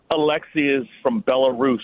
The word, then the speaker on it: Aliaksei: A-L-I-A-K-S-E-I. – I mean, that's to me, that's Alexei.